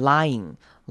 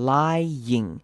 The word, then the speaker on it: lying: If you're doing anything deceptive or manipulative, or you're lying or hiding something，好，注意到 lying 这个字，大家很常念错，很容易念成 lying，这样就念过去了。但其实 y 跟 i 是两个声音，要各自独立出来念，要念成 lying，那个 ing 前面要一个 y 的声音出现，lying，记得这个字是两个音节。